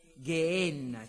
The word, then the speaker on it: Geenna: e Geenna, o Geenna come generalmente leggiamo, ma sicuramente una lettura e una pronuncia più precisa è Geenna, che viene utilizzato 12 volte nel Nuovo Testamento.